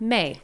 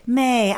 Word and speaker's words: Mai: And her name is M-A-I. How would you say that? – May. – May. I just love it, but it's M-A-I.